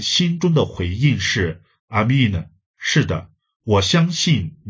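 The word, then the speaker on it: Amen: 你知道先知书是用希伯来文写的，在希伯来文相信的读音是 Amin，我们读娃的时候说 Amin，就是从希伯来文来的。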